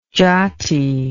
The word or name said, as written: jati